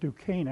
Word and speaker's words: Duquesne: And my wife keeps saying, I don't know how you get Duquesne out of that. And I said, I don't know either. I don't speak French. But I know that that's how that's pronounced.